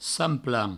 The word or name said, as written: sant-plan